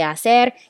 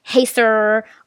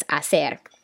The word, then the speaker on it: hacer: The H is silent. So when you say hacer, you're not saying hey sir or hasser. It's hacer.